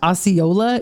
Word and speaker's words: Osceola: Osceola. Yeah. My spelling of this is wrong, but that's how it is pronounced.